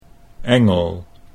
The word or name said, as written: Engel